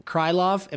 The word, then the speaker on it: Krylov: I should have read this headline a little more in advance because I know Ankalaev is the last name, but Krylov, am I saying that right, PT? Yep. Rob? Okay, cool. Yeah.